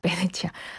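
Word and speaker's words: Venezia: 베네치아. 베네치아와 이것 같은 도시를 가리키는 말이고 다만 이제 언어에 따른 발음 차이. 베네치아는 이탈리아 현지어 발음이고 오늘 이 보기에 내드린 정답은 영어식 발음이라고 생각하시면 될 것 같습니다.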